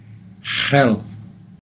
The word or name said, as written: xiil